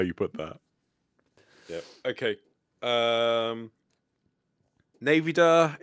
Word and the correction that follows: Nvidia: Nvidia. You're almost saying Nvidia, but they don't have Nvidia actually, but like Nvidia. Nvidia. Something like that.